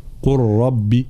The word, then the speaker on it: قل ربي: إذن اللام الساكنة تدغم في الراء المتحركة فيصيران حرفا واحدا من جنس الحرف الثاني وهو الراء، فيصيران راء مشددة، لا ننطق اللام، قل ربي قل ربي هكذا، هذا إدغام يسمى.